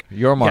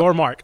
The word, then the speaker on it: Yormark: Yormark, yes, yep. Yormark.